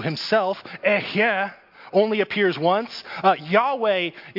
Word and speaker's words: Yahweh: That is a stand-in for this Hebrew word, which is pronounced Yahweh.